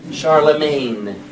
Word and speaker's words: Charlemagne: M-A-G? How do you pronounce it? M-A-G-N-E. Magnet. Charlemagne. The G is silent.